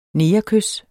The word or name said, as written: negerkys